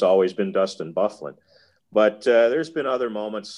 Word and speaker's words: Byfuglien: And I thought you ought to know that number 33 pronounces his name Bufflin. I said, you gotta be kidding me. I said, how do you get Bufflin out of that combination of letters? It can't be that. He goes, well, I'm just telling you that that's how he wants his name pronounced.